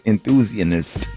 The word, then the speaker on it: enthusiast: Because you know I'm a roller coaster enthusiast. Did I pronounce that the right way? I did. – Enthusiast. – What did I say? – You said you threw an N in there.